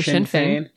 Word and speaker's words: Sinn Féin: You said in Parliament, was that the Sinn Féin? And am I saying that correctly? – You are saying it correctly. Sinn Féin.